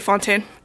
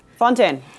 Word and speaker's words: Fontaine: How do you say that in French? – I would say Fontaine. – Fontaine.